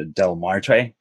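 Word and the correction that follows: Día de los Muertos: Dia de los Muertos.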